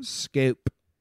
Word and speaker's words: Schoop: Scoop, Shoop, Jonathan Schoop. Is that how you say it? Scope. Scope, Shoop, Scope, Shoop. Like it's scope.